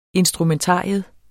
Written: instrumentarium

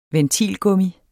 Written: ventilgummi

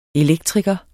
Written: elektriker

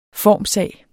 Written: formssag

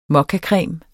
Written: mokkacreme

